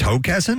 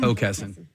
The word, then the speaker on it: Hockessin: It's Hockessin? – Hockessin. – Well, don't spell it like hockey, bastards. – Yeah. Come on, like all those Kessins are hoes. You know? – Yeah. – The C is silent.